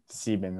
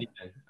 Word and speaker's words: Sieben: Sieben, that's right. Sieben.